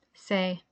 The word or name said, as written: Se